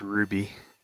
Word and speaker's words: RWBY: It's pronounced Ruby.